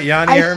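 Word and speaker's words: Jani Hurme: I believe it was Yanni Hermé. Is that how you say it? – That's right, Yanni Hermé.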